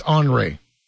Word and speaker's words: Henry: So it's spelled Henry, but it's Henri.